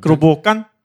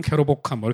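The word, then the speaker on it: Kerobokan: 크로보칸? – 크로보칸. 깨로인데 거기 발음으로는 끄로라고요. 크로보칸. 영어 발음은 캐로보칸 뭐 이렇게 나와요.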